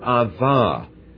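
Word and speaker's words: Ava (עָוָה): And in English, spell it A-V-A-H with a little apostrophe on the front. And the little apostrophe means make your uvula inside of your mouth go click before you say the ah. Anyone practice it? Ava is the pronunciation of the word.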